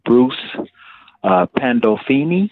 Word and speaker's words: Bruce Pandolfini: Some other chess books that I've got off into, I want to say, now correct me if I'm saying his name wrong, Bruce Pandolfini.